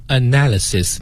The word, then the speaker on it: analysis: analysis, 你看啊，它是 analyze 的一个名词形式，但它读音跟 analyze 不一样，中音也不一样，后面那个 lies 和这里的 analysis 也是不一样的哈。 – 好，咱们把这个名词 analysis 给大家拼一下。 – 好，是 A-N-A-L-Y-S-I-S, analysis。